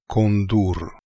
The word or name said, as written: Kondoor